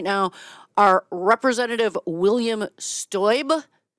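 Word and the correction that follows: Steube: or Stuby, excuse me, that is how it's pronounced.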